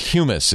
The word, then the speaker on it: humus: But it's pronounced humus if it's that, right? – Yeah.